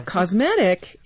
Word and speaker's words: cosmetic: What's happening in the word cosmetic, the T in that case is being pronounced as, it's almost like a D. And that happens in North American English when you have a T between two vowels.